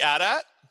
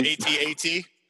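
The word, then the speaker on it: AT-AT: Warriors, and he had the Adat Walker when that came out, and I was like, he's also and and, and thank you for pronouncing that correctly. The Adat. Yes. As opposed to ATAT?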